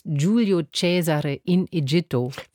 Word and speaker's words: Giulio Cesare in Egitto: Am Sonntagnachmittag gibt es noch einmal Friedrich Händels Giulio Cesare in Egitto.